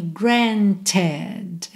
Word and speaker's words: granted: Quando o verbo regular termina com T ou D, esse E vai ser pronunciado. Por quê? Eu preciso dessa vogal para linkar. Então, eu tenho granted.